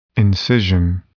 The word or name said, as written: incision